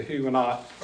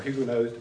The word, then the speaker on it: Huguenots: the Huguenots, or Huguenots, depending upon whether you use an American French pronunciation, were being thrown out of France.